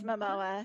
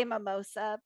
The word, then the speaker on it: Momoa: It's Momoa. Okay. I always want to say Mimosa, but it's Momoa.